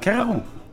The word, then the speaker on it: Kereru: Bevor ich überhaupt irgendwas zum Bier frage, einmal die erste Frage: Wie spricht man es eigentlich richtig aus? Keraru wird es wohl nicht sein, oder? – Doch, genau so ist es. Keraru.